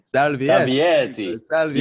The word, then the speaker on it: Salvieri: Salviti. – It's Italian, so it's Salvieri. – Salvieri.